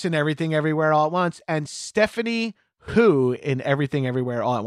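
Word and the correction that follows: Hsu: Did I say her name right? – I think it's Sue. – It's Sue.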